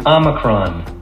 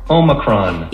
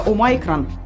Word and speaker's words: Omicron: Словарь Мириам Вебстер, например, предлагает такие варианты произношения. – Омикрон. – Omicron. – Именно так это название произносят во Всемирной организации здравоохранения. Omicron. В то же время новый оксфордский словарь предлагает другой вариант произношения Омайкрон. – Omicron. – В греческом же языке, то есть в источнике, это слово произносится с ударением на первый слог «омикрон».